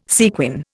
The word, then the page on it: sequin: Indian